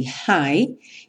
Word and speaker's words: high: Novamente temos aí o G e o H pintados de cinza, ou seja, a pronúncia desse hi, que significa alto, é igual à pronúncia do hi, que significa oi. I say high, you say low.